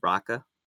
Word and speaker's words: Rakka: The main one that T's talking about stars Sigourney Weaver, and it's called Raka. I think Raka, I'm saying it right. R-A-K-K-A.